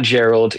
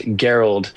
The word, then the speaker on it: Gerald: There's a great a tech there named Gerald, not Gerald, Gerald. And it's spelled like gear with an LD on the end.